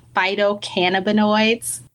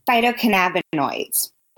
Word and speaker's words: phytocannabinoids: Did I pronounce that right? Phytocannabinoids? – Phytocannabinoids. But you were so close.